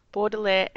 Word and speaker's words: Baudelaire: So now today we're going to be discussing your latest book, Baudelaire and the Great Beyond.